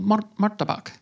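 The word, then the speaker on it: martabak: So in Indonesia, there's something called Marktabak. You got to roll your R. Is that the dessert? Marktabak.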